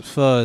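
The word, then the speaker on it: phở: Eat pho while we... – It's pronounced pho.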